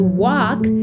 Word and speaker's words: walk: She was walking, estaba caminando, to walk, caminar, W-A-L-K. Recuerda, amiguito, que la L antes de la K no se pronuncia.